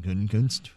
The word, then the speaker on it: Gutekunst: Yeah, a lot of confidence there from Brian Gutekunst.